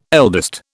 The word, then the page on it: eldest: British English